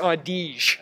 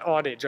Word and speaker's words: Audige: Chase Audige. – Oh, yeah. – That's how you say it, not Audige, like I said last game.